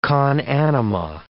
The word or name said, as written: con anima